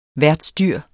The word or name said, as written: værtsdyr